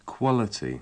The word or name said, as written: quality